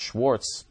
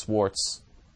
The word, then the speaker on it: Swartz: It's not Schwartz, it's Swartz. S-W-A-R-T-Z.